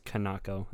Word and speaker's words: Kanako: I'm going to guess it's pronounced Kanoko, but that might just be Americanized. – I'm pretty sure it's Kanako. That's how it's spelled. – But, you know, Kanoko sounds better.